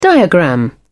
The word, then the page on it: diagram: British English